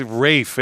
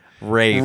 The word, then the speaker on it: Ralph: Rafe. – Rafie Fiennes. – Rafie Fiennes. There That's the go. Way to do it. – There yeah. – you go. Rafie it's spelled Fiennes. Ralph, but it's Rafe.